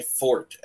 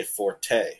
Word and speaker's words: forte: Notice I didn't say forte. Forte is Italian, and it means louder. Fort is how you pronounce the word from French, meaning the strong part of the blade, something you're good at.